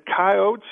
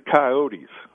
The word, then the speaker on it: coyotes: Is it coyotes or is it coyotes? – It's pronounced both ways. I call them coyotes. That's kind of the, you know, common way of saying it. But a lot of people call them coyotes, too.